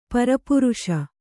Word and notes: ಪರಿಪೂರಿಸು: pari pūrisu; Kannada

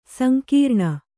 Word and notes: ಸಂಗ್ರಾಣ: sangrāṇa

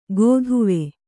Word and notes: ಗೋದುವೆ: gōduve

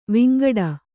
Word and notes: ವಿಂಗಡಿ: vingaḍi

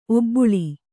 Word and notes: ಒಬ್ಬುಳಿ: Kannada

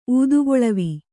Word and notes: ಊದುಕೊಳವೆ: ūdukoḷave

